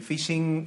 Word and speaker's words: phishing: El phishing eh, se escribe, en este caso es eh, P-H-I-S-H-I-N-G, eh, y está escrito de tal forma que fonéticamente en inglés coincida con phishing.